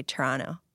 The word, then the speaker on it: Toronto: And I'm making a point of saying Toronto, because in Toronto, we just say Toronto.